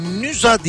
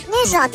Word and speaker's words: Nysa: Şimdi NYSA diye yazılıyor. Nüza diye okunuyor. – Evet Nüza diye evet, okuyorlar.